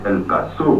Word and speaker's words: El Paso: Bijou El Paso, je précise bien qu'on prononce El Paso.